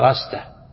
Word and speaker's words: رستة: قال أنبأنا محمد بن الفضل بن عبد الرحمن قال حدثنا محمد بن عبد الله بن رستة ابن رستة رستة أي هي كلمة تكتب بالألف لكن العرب ينطقونها برستة لكن تنطق هكذا رستة.